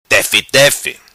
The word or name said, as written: Tefi tefi